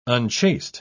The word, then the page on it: unchaste: British English